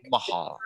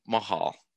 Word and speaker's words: Mahal: Mahal, like Mahal. – Taj Mahal. – Okay, love it. Okay, cool. – Taj Mahal, but it's pronounced like that.